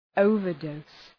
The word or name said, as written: overdose